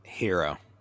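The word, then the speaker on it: Herro: Hero.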